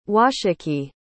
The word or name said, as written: washakie